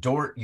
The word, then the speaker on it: Dordt: This week's spotlight on the 30 under 30 and the current head women's coach at Dort University in Iowa. If you want to look that up, folks, it's spelled D-O-R-D-T.